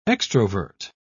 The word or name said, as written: extrovert